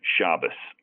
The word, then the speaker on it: Shabas: The way my grandfather pronounced it was like the Z was like an S, Shabas.